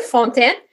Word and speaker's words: Fontaine: So I'm glad that you were able to pronounce my last name pretty good, Fontaine. So everybody knows that this is a French name.